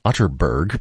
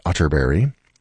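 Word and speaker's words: Atterberg: It's spelled Otterberg, but pronounced Otterberry. I have it on good authority.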